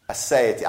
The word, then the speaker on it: aseity: Aseity. I'll spell it because I think it's one of the answers in the book. A-S-E-I-T-Y.